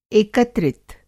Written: एकत्रित

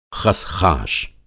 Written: ख़शख़ाश

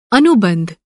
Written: अनुबंध

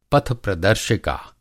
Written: पथप्रदर्शिका

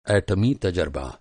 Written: तजरबा

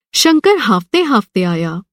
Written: हाँफ़ना

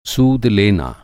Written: सूद

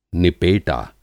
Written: निपेटा

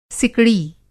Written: सिकड़ी

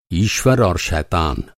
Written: शैतान

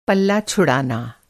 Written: पल

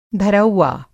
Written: धरौआ

